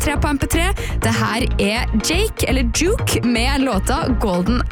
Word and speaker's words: JVKE: Han har ønsket seg en låta Golden Hour av artisten, som jeg tror uttales Jake. Det skrives I hvert fall J-V-K-E, så jeg litt usikker på hvordan det uttales, men det her en skikkelig god høstspillelistelåt.